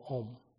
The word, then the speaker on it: Om: So here, most of the mantra, the beginning of the mantra, when we recite the mantra, you will find the syllable Om. The syllable Om is consists of three letters, three syllables. Auma, it becomes an Om.